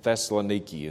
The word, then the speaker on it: Thessaloniki: For the Bereans were more noble than those in Thessaloniki, in the way it's, the town's pronounced in Greek, in that they received the word with all readiness of mind, searching the Scriptures daily to see whether these things were so.